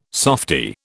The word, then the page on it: softy: British English